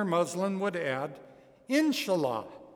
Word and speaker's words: inshallah: One of these words is inshallah. Let's say it together. Inshallah. If you really wanted to say it right, it'd be insha'Allah, but we're Americans.